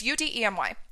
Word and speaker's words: Udemy: I know, is it Udemy? Udemy? How do you pronounce that? I don't even know. It's Udemy.